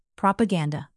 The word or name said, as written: propaganda